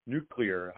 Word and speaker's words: nuclear: Nuclear. How do you pronounce it? – Nuclear. – Nuclear. – No, don't do the George Bush thing. That's nuclear. No, it's nuclear.